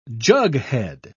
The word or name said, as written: jughead